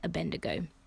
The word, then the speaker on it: Abednego: How do you say their names? Shadrach, Meshach, and Abednego. That's how you say it.